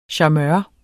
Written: charmeur

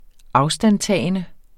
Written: afstandtagende

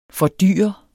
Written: fordyre

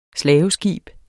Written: slaveskib

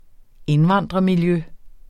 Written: indvandrermiljø

